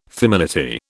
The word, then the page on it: feminity: British English